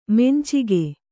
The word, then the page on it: ಮಂಚಕ: mancaka